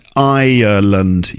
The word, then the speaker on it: Ireland: Are those words pronounced the same? Island and Ireland. I think there's more syllables in the name of the country. So island, you know, like a desert island, and Ireland. You hear it? Ireland. Slightly different, actually. But some people might pronounce them the same.